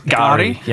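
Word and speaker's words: K'gari: I think it's pronounced Gari. – Yeah. – But the K apostrophe is because the G has quite a glottal. – Right. – Gari, the pronunciation device is just like treated as silent.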